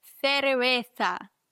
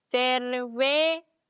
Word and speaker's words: cerveza: Weil ein C vor E und vor I wird gelispelt und ein Z wird immer gelispelt. Also Cerveza. Cerveza. Gar nicht so einfach. Und dann kommt auch noch dazu, dass wir auch noch ein V in dem Wort haben, was gerne wie ein leichtes B ausgesprochen wird. Dann haben wir Cerveza. Cerveza. Cerveza. Merkst du das V so ein bisschen wie ein B?